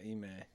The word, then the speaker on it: Ime: Ime, is that how you say it? – Yeah, Ime.